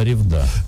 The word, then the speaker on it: Ревда: Я сейчас посмотрел на ударение, я тебе сказал, что Ревда, правильно, Ревда — это в Мурманской области, а вот на Урале Ревда. Это Ревда.